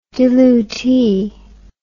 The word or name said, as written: dilutee